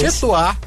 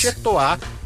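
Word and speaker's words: Chez Toi: Chetois, underline, em seu lugar. Chetois se escreve C-H-E-Z, e Toar se escreve T-O-I. – Isso. – Chetois, underline, em seu lugar, é o Instagram, é o perfil do espetáculo.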